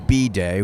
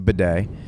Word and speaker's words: bidet: You pronounced it B-day, which is real annoying. It's a bidet.